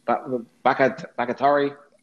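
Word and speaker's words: Bakhtiari: How do you say that name? Bakatari? – Yes. – There we go.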